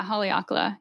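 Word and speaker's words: Haleakalā: Well, I definitely believe that you can do it, especially because the place that you found in Maui was on, I hope I say it right, Haleakala? – Yeah, Haleakala. You got it.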